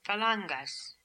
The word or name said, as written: phalangas